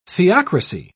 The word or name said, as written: theocracy